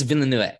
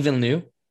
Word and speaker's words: Villeneuve: Is that how you say it? – I think it's Villeneuve. Yeah, I watched a video of him. – I thought it was Denis Villeneuve. That's what I've heard.